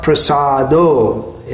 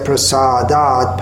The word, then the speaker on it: Prasada: And it's not Prasado. In the first line of the eighth verse, we have Yasya Prasadat Bhagavat Prasado, but here it's Prasada. It changes the meaning if we mispronounce it.